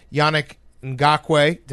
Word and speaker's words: Yannick Ngakoue: The first is everyone tweeting about the Jaguars, how short a defensive window is because Yannick Ngakoue.